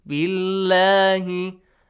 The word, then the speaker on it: بالله: The word Allah, the lam of the word Allah. We have a kasra sound before, yes? So is it heavily pronounced, Fawad, or lightly? – Lightly. – Lightly. Billahi amanna billahi wa bil yawmil akhir.